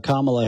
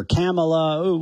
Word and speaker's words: Kamala: I think I called her Kamala or Kamala, whatever, right? And one of our liberal listeners was, it's Kamala.